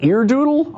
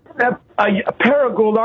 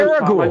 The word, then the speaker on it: Paragould: In how do you say that? Eardoodle, Arkansas? – No, Paragould, Arkansas. We've spoke several times. – Paragould.